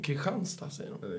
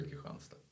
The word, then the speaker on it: Kristianstad: Hur uttalar de? Kristianstad, säger de. Det heter Kristianstad.